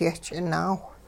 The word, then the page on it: yehch'inaawh: hupa